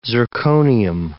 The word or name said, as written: zirconium